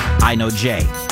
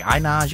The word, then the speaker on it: Inoj: a real name, Yana Porter, but we know her as I Know Jay. And think about how long it took you to really understand that's how you pronounce I-N-O-J, right? Like, there's some people, I remember friends of mine back in the day, like, I Nas, you like this song? I'm like, what are you talking about?